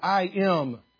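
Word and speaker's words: im (עם): That the first letters there, the I-M, would be pronounced im in Hebrew.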